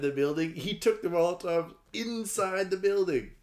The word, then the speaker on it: Molotov: Tokyo by throwing Molotov cocktails. – Molotov. – Did I say it wrong? – Yeah. – Oh, you got the German pronunciation. – Molotov.